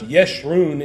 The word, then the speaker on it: Jeshurun: It's a title for Israel, which literally means the upright one, Jeshurun. It would be pronounced Yeshurun in Hebrew.